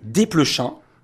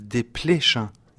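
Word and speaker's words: Desplechin: faut prononcer « des Pléchins » ou « de Pléchins Ah, on prononce comme on veut. À Paris, souvent les gens disent des pléchins, non? – ». Et vous prononcez comment dans votre famille? – Dans notre famille, on prononce des pléchins.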